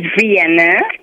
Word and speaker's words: Vienna: It's Vienna. V-I-E-N-N-A.